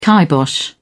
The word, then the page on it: kibosh: British English